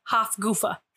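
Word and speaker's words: Hafgufa: It's G-U-F-A, not G-O-O, like goofy. – Okay. I did a strong U, goofa. Hufgufa. – It'd be like guffa.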